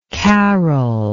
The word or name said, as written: karol